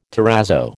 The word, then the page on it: terrazzo: American English